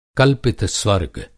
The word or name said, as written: कल्पितस्वर्ग